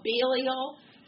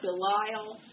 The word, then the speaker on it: Belial: The spirit behind that is called Belial. Some people call it Belial. In the Hebrew, it's Belial. So take your pick, which syllable you want to emphasize.